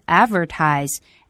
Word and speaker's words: advertise: 在这里我们来说一下 advertising, 这个词呢是来自于动词 advertise, A-D-V-E-R-T-I-S-E。这个词要注意一下重读音节是在第一个音节上。